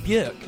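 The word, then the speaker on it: Björk: But before that, we're going to go for a song by Bjork.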